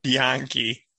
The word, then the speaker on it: Bianchi: Yeah, Bianchi. – It's some Italian pronunciation for you.